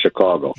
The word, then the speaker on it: Chicago: You're kind of in between Chicago and Chicago, yeah, but right. It's Chicago.